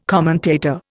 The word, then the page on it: commentator: Indian